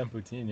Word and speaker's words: poutine: Yeah, poutine, yeah.